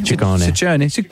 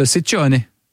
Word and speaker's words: Ciccone: Well, in Italian, it works the other way. So the double C's are hard C's and the singular C's are soft C's. – So Ciccione.